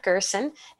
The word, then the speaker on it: Gerson: Well, first of all, again, my name is Barb Gerson. And if you go to my LinkedIn and you play the little audio sound, you'll hear it rhymes with special person.